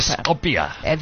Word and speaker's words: Txopela: It's spelled TXOPELA. That's the name of the newspaper. – Scopia. – There you go.